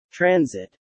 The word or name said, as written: transit